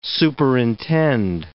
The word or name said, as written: superintend